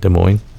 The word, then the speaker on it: Des Moines: They beat Des Moines, the team from, how do you say it, Des Moines? – Yes, that's how you would say Des Moines.